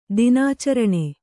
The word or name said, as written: ದಿನಾಚರಣೆ